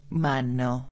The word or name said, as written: mano